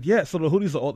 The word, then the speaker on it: Houthis: Yeah, it's the Houthis.